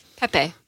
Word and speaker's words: Pepe: Is it pronounced PayPay or Pepe. – Pepe.